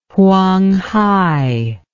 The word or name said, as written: Huanghai